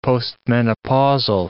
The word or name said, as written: postmenopausal